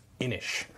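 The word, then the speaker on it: Inis: Today we're going to take a look at Innis, or I think it's pronounced Innish.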